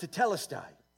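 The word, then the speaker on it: tetelestai (τετέλεσται): And it actually is pronounced tetelestai in Greek.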